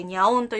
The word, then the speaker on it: 猫音: あのアトリエと書いて、あと猫音と書いてニャオンというところで、